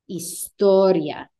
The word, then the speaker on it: historia: IA endings, you're only going to emphasize it if your I is accented. Otherwise, it needs to fall on the syllable beforehand. Memoria, historia, gastronomia. There I pronounce the, or there I emphasize the I.